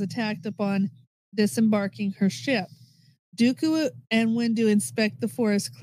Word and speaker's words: Dooku: Dooku remarks... direction of the starship. – No, you're fine. I'm just... – Starship. – Dooku, you said it right.